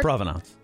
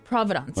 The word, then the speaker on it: provenance: How do you say that word? – Provenance. – Providence. – Provenance. Providence. Whatever.